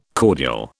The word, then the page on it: cordial: British English